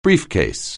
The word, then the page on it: briefcase: anglais